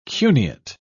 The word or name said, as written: cuneate